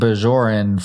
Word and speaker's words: Bajor: Well, there are two things now, but it does make sense the way Patrick Stewart pronounces it. Because, like, well, I've been saying Bajoran for Ro in all those episodes. Yeah. So it's Bajor, right? – Yeah.